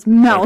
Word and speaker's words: Melch: Because it rhymes with Belch, but it's with an M, so it's Melch.